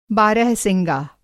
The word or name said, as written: बारहवसिंगा